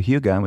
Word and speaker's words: hygge: It's spelled H-Y-G-E-E. But it's this ancient old art of cozy. And the Danish called it hygge. – Oh, I know what you're talking about, but I would have never pronounced it that way. – It looks like hygge. – Yes. – Or hygge. Hygge. It's hygge.